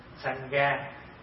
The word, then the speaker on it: Sangha: Bởi vì phát âm từ cái chữ Phạn là Sangha. Sangha, Sangha thành âm lại là tăng già.